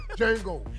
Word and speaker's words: Django: The D is silent. – Yeah. D is silent. – Yeah, Django.